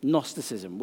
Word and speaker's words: Gnosticism: Here's another heresy for you, which is called Gnosticism. Gnosticism, which annoyingly is spelled with a G at the start. Who knows why?